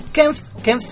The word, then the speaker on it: Kempf: No sé bien cómo iría la mano, pero estamos hablando puntualmente de Fede Kemps. Kempf, se escribe K-E-M-P-F-F